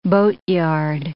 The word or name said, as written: boatyard